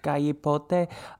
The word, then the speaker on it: Galipote: Am I saying that right? Galipote. El Galipote. – Galipote.